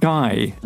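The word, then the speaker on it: GAI: It was someone called Guy, which is spelt G-A-I, a rapper whose early songs about drugs and violence once were deemed unfit for public airing.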